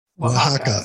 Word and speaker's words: Oaxaca: Oaxaca.